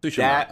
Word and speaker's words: Tsushima: Man, Tsushima.